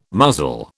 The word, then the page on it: muzzle: British English